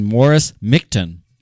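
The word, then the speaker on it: Morris Michtom: Now, meanwhile, after this 1902 thing, a Brooklyn, New York candy maker, you bring candy into it, named Morris Mictum. I had to look that up because it's not spelled the way it's pronounced.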